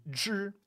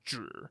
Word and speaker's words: 只: So like little things that have some life to them is when you'll use zhi in first tone. And normally it's pronounced zhi, third tone and meaning only.